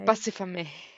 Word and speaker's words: Bussifame: Because when she says it, so she's saying bust it for me, but she says bussifami. – Bussifami. – You can't even say it. – No, that's what it sounded like, was bussifami. Yeah, but it isn't spelt bust it for me as the one word. It's spelled B-U-S-S-I-F-A-M-E.